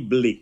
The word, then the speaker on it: Bleyl: Tommy Blé. Alors, ça s'écrit. Ça s'écrit pas comme le blé dans nos champs, là. Ça s'écrit B-L-E-Y-L. Blé.